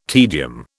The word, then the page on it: tedium: British English